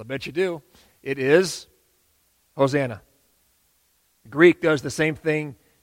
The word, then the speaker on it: Hosanna: This word Hosanna in Hebrew, you pronounce it a little bit differently. You'd pronounce it things like maybe Hoshiana or something like that.